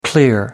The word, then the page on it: clear: American English